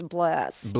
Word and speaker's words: Blessed: But the album is, the correct pronunciation is blessed. – Blessed. – All right. – Not blessed.